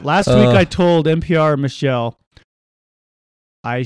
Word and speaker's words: Michel: Michael or Michelle? – Well, it's spelled M-I-C-H-E-L. It's Michelle Martin.